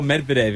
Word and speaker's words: Medvedev: Daniel Medvedev. If I can pronounce that correctly. – Yeah, correct pronunciation.